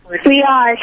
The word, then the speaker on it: Riaz: Hi, my name is Riyaz. I think it's mango. – Mango. – I mean strawberry. – Strawberry. Who's this? Yash? Did I get your name right? – Riyaz. – Spell it. – R-I-A-Z.